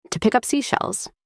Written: to pick up seashells

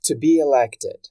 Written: to be elected

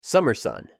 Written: summer sun

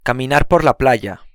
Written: caminar por la playa

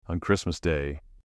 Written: on Christmas day

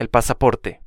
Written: el pasaporte

